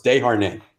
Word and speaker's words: Desharnais: David Desharnais. It's French. – Is it? – It's spelled like Desharnais. – Desharnais. – But you got to put the French on it, so it's Desharnais.